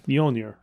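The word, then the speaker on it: Mjölnir: Mjolnir.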